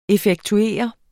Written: effektuere

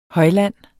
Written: højland

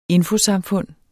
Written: infosamfund

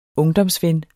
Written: ungdomsven

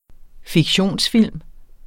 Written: fiktion

